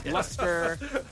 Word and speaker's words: Leicester: And a lot of people wondering how to pronounce it because it's not spelled as you would pronounce it. It's not spelled Leicester, like L-E-S-T-E-R. It's L-E-I-C-E-S-T-E-R. And I've seen some videos. The Wall Street Journal had a video on their social media where they ask people, how would you pronounce this? And then they tell them, and you get people saying Leicester, Leicester, Leicester. – But it's Leicester. – It's Leicester City,